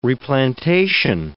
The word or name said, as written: replantation